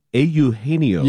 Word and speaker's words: Eugenio: Or as Pat Hughes says, A. Eugenio. – Yeah, I heard him on the call the other day. – Every vowel sound, A. It's not you, A. Eugenio.